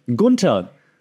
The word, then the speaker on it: Gunther: Right now, for example, Sami Zayn facing Gunther, then the Ring General, and that's how you pronounce it, by the way, because I'm German and Gunther is Austrian, so we have the same language because Austria doesn't have our own language.